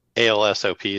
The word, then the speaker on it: Alsop: When you say ALSOP, is that the name? Is that how it's spelled? – ALSOP, yep. – Yeah. It's actually my mom's maiden name. It was Alsop, is how they pronounced it.